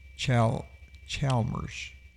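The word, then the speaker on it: Allis-Chalmers: It's Alice Chambers. It's Chalmers.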